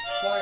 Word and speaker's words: choir: How do you pronounce it? – Choir album. – Choir, yeah. – Because it's C-H-O-I-R. Choir, okay.